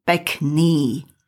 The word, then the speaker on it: pekný: Masculine adjectives in singular end in Y, pronounced E. For example, pekný.